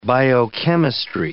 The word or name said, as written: biochemistry